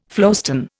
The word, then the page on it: flowstone: Indian